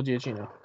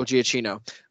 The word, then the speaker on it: Giacchino: Michael Giacchino. Gotcha. – Michael Giacchino.